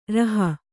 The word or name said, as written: ರುಹೆ